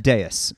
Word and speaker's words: Deus: So how is it correctly pronounced? – Deus.